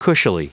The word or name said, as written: cushily